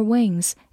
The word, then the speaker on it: wing: 最后提醒大家，有一个词跟它发音很像，w i n win i n 字母组合发的是前鼻音 in win，动词名词表示赢获胜，而我们今天学习的 i n g 结尾是一个后鼻音 wing。 wing，发音和拼写都有微弱的差异，希望大家可以仔细体会一下。